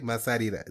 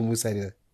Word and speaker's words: Masarira: Masarira. Is that how you say it? Is it Masarira or Musarira? – Musarira. Linda Musarira. – Musarira. Yes.